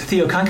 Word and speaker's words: Theocon: Uh, Theocon, Matt, which is spelled with a theta instead of a th for our fellow Greek nerds, uh, pronounced Theocon, combines two words, theology and convention.